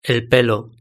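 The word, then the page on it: el pelo: Spanish (Spain)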